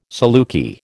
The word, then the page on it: saluki: American English